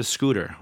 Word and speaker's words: XOOTR: and that is I'm going to get a scooter made by XOOTR, pronounced scooter, but with an X.